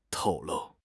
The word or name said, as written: 透露